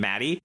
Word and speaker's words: Muddy: I also want to say, Tucker, is a fun fact, where the Japanese pronunciation of Muddy apparently is pronounced like the name Maddie, so that's, like, supposed to be, like, a meta joke that just doesn't translate.